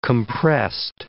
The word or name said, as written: compressed